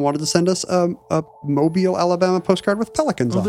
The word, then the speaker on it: Mobile: I said that wrong before. – Mobile.